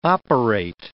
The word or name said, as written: operate